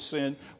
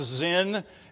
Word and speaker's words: Sin: Even though the Israelites had some problems with sin while they were in this wilderness, that's not the idea. It's just a name. It can be pronounced Zin as well as sin, and so don't be confused by that.